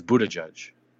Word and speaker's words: Buttigieg: Oh, if that if that family said it, but the politician is Buttigieg. – Well, what makes his right? – Because that's the way he pronounces it.